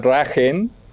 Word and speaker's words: Drachen: En alemán, la palabra dragón se escribe Drachen, que se pronuncia Dragen, pero yo no quería que nos dijeran los Drachen.